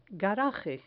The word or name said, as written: garaje